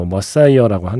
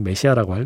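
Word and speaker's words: Messiah: 원래 발음대로 하면 뭐 머사이어라고 하는데 네, 그냥 메시아라고 할래요.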